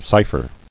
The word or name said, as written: Cypher